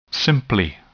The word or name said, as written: simply